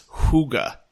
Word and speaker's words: hygge: I actually just looked up the word. It's hygge. – Okay. – It's spelled H-Y-G-G-E.